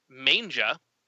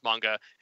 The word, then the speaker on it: manga: Uh, we later found out, or I later found out it's pronounced manga and it's not the art of Japanese comic collecting.